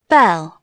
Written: bell

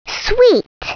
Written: sweet

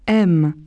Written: aiment

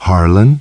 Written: Harlan

